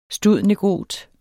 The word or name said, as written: stud.negot.